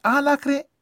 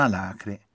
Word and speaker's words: alacre: Al contrario si direbbe alacre e non alacre, con l'accento sulla prima vocale.